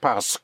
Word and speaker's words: parce: The S into the park makes it parce. Parce. – Parce. – Parce. Yes. Right. In spelling, it's spelled P-A-R-C-E, Q-U-E, in two words, parce.